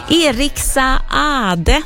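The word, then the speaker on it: Eriks Saade: Eriksa Ade, så har jag ju lärt mig att man ska uttala hans namn. Han stavar ju S-A-A-D-E och då ska man tydligen uttala båda A-N också, så då gör det.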